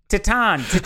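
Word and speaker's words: Titane: That's how you say it. Titan.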